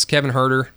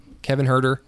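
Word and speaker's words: Kevin Huerter: Kevin Herter. Is that how you say his name? – Mm-hmm. – Kevin Herter. – Nailed it.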